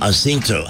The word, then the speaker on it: Jacinto: Jacinto. I think that's what Mrs. Palermo, my high school Spanish teacher, would have insisted on.